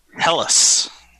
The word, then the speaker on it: Helles: It's, I probably won't drink this tonight, but it's called Helly. I don't know if it's how you pronounce this. Hells or Hellies in the tropics? It's Helles. I don't know what that is, but Helles. – Helles.